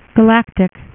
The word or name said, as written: galactus